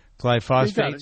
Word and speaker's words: glyphosate: So the new one this week, the connection of Lyme disease and glyphosate. I've never pronounced that right. Glyphosate. – Glyphosate.